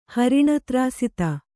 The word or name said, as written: ಹರಿಣತ್ರಾಸಿತ